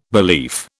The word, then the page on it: belief: British English